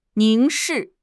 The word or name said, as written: 凝视